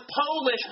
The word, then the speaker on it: Polish: And this is Madame Curie killing herself by discovering radium, who, although she was a naturalized French citizen, was Polish by birth. – 这里需要特别注意一下 polish 这个单词呢，如果 P 小写它读作 polish，它指的是我们就是做美甲的时候的那个动作，磨光打光那个动作。但如果呢将 P 大写，它就变成 polish 了，这个 O 的发音呢会有变化。